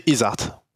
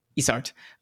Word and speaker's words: ISART: Is that, how do you say the name of the school? – Yeah, we say ISART. – ISART.